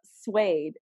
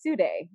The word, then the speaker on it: suede: Hers for mine is that I saw suede and I instantly thought it would be pronounced Sude.